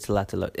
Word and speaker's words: Tlatelolco: Okay, so the stop is actually Tlatelolco. And that's spelled Tlatelolco. See, that's why I couldn't pronounce it. – Yes, it comes from the Nahuatl language. So that was what the Aztecs spoke.